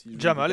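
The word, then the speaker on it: Jamaal: J'ai peur d'écorcher son prénom, mais c'est Jamal. Hein, Jamal, exactement, Jamal Williams.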